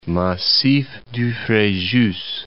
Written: Frejus, Massif du

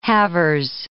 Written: halvers

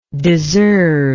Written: deserver

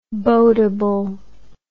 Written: boatable

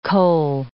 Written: kohl

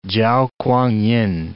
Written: Chao K'uang-yin

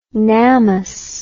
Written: Namath

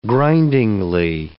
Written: grindingly

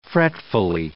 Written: fretfully

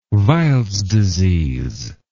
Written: weils disease

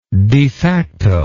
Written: defacto

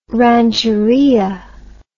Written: rancheria

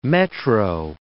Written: Metro

